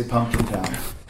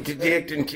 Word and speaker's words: Penticton: Can I just do a quick segue of Heath Slater's pronunciation of Penticton? – Oh, every time I have this conversation, he did actually once say Pumpkin Town. – Okay, so him, R-Truth actually. – Pumpkin, Penticton, you know, I think, you know, Canada, where breezes from.